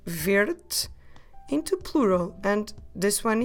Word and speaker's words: verde: Verde. I will spell it for you. V E R D E. But although it ends at E, we drop it when we pronounce it. So we say verde, verde.